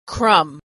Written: crumb

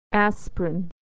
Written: aspirin

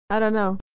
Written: dunno

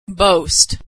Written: boast

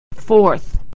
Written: forth

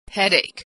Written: headache

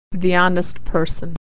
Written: honest person

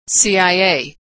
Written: CIA

